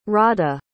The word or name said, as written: rada